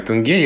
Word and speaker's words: тенге: Ударение в котором ты всегда путаешь, но сейчас ты сказал верно, я у штудинера проверял. – Спасибо, да, я всегда путаю ударение в этом слове, и сколько бы я ни запоминал, что правильно «тенге», я через 10 минут об этом забуду и опять буду сомневаться.